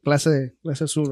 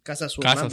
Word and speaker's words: Clase Azul: They bringing us over here una botellita de tequila de clase azul, right? – Clase azul.